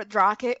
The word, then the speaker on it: Drache: We all know it's pronounced Drasha. – Drasha? – It's actually pronounced Drash or Drache or Drache, depending on the language you try to pronounce it.